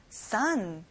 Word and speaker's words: son: o가 있지만 어 소리가 안 나고 아 소리가 나요. son.